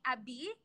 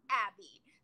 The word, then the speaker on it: Abi: And people in Brazil call me Abi, not Abby.